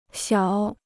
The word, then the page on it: 小: xiǎo